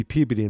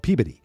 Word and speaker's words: Peabody: It'd be Peabody in Massachusetts. That's my Massachusetts accent. We have a town called Peabody, but it's spelled Peabody.